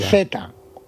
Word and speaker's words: Zvyagintsev: Es Yajinstev, ¿cómo se pronuncia eso? – Creo que es buenísima. – Zvyagintsev. – Sí, ya está.